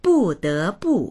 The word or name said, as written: bu4de2bu4